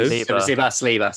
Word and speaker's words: Saliba: Like it's going to be Sabala. Did I say that right? Yeah. William Saliba. Saliba.